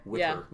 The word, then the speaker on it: Witter: Witter.